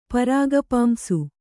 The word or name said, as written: ಪರಾಗಪಾಂಸು